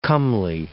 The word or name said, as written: comely